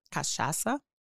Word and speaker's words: cachaça: How do you say that? – Cachaça. – Cachaça. – Perfect. Okay. Yes.